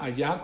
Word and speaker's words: hayah: Así como se pronuncia allá, como si fuera allá en español, pero es H-A-Y-A-H. Allá, es el mismo allá pero con H al final.